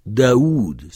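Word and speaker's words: Daoud (داود): Daoud s'écrit comme ça, alors parfois il y en a qui mettent deux waouh, mais ça c'est l'orthographe consacrée depuis des siècles. Et ça se prononce Daoud, c'est David, évidemment.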